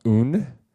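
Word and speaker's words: un (οὖν): And I looked up the word so, and in Greek, I think it's pronounced un, O-U-N, and it just means, it's usually translated therefore, or so, or then.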